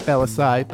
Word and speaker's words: Bellisai: You can find me on all of the social media at Matt Bellisai. That's Bell-ass-A-I. Don't spell it wrong.